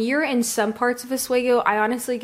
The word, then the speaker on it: Oswego: So what's it like, though, in Oswego? Like, is it like, or did I say that right? – Yes. – Is it Oswego or Oswego? – No, Oswego. – Oswego.